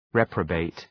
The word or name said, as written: reprobate